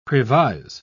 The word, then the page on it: previse: British English